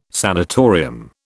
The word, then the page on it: sanitarium: British English